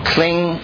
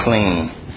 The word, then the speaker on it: Klim: Now, I know it says klim, but you see the pronunciation underneath is kling. So you don't say kling. You say kling, like a nasal, you know, kling.